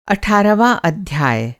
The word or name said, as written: अठारहवाँ